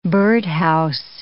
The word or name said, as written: birdhouse